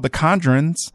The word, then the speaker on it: The Conjurers: It's called The Conjurers. Yeah, that's how you say it.